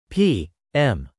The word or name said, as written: p.m.